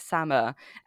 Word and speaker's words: Samar: It's two syllables. It's Samar.